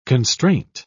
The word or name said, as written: constraint